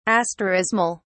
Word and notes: asterismal: British English

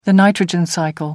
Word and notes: nitrogen cycle: American English

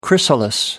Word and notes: chrysalis: American English

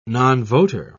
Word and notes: nonvoter: British English

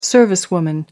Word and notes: servicewoman: American English

